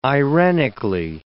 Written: irenically

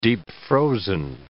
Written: deep-frozen

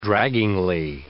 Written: draggingly

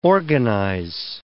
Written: organize

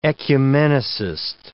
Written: ecumenicist

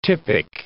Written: typic

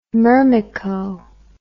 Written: myrmeco

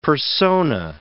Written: persona

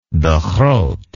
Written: de groot

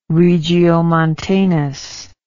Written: regiomontanus

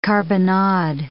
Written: carbonnade

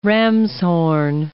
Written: ramshorn

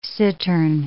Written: cittern

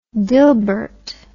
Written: dilbert

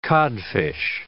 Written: codfish

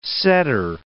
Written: setter

